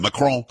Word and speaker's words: Macron: You have to say it like that, Macron.